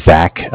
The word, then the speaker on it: Zach: My name is Zach, spelled Z-A-C-H. And was just following up from Cardiff. Cardiff is spelled C-A-R as in car, D-I-F-F as in Frank Frank, like Cardiff, Wales in England.